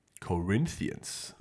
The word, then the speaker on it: Corinthians: Corinthians, okay.